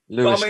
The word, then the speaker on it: Lewis: He can pronounce, I suppose, his name the way he wants to. – He can. – And he pronounces it Lewis.